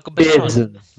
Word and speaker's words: Besson: Besson.